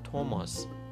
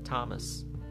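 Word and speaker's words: Thomas: بزنین ببینیم که این اسم اصلا چجوری تلفظ میشه در زبان انگلیسی سپلینگش چیه و من یه توضیحاتی هول هوشش بدم آنچه ما میگوییم توماس آنچه آنان میگویند تامس تامس اصلا دقت بکنید چقدر تفاوت هستش آنچه میتوان گفت تامس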